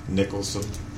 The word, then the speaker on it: Nicholson: My name is Earl Tucker Nicholson. E-Earl, E-A-R-L, Tucker, T-U-C-K-E-R, Nicholson, N-I-C-H-O-L-S-O-N.